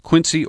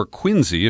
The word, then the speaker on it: Quincy: Now, in the piece that we're going to talk about today, published at Tom Dispatch, it describes you as co-founder of the Quincy, or Quincy, if we use the Boston pronunciation, the Quincy Institute for Responsible Statecraft.